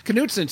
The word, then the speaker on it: Knutson: Knutson. See, it's actually pronounced correct because I went to high school with people called Knutson, and it's like people out here in California don't understand the K is not silent.